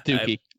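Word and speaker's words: Doukie: Or Dowkey. I don't know how you say it. I think it's Dookie. – Dookie. Like the Green Day album.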